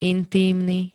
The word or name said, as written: intímny